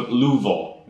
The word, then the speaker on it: Louisville: So Louisville, as they call it. – That's how they say it.